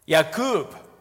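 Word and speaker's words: Yaqub (יעקב): Jacob, which is pronounced in Hebrew, Yaqub, sounds similar to the word for heel, Akub, or the verb Akub, meaning supplant and deceive.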